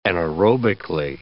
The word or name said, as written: anaerobically